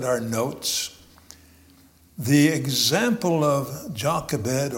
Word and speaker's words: Jochebed: The Hebrew pronunciation is Jochebed.